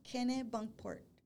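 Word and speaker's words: Kennebunkport: Kennett Bunkport. Hold on. Ken, Kenny Bunkport. Like Kenny Bunkport.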